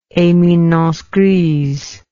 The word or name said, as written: eminence grise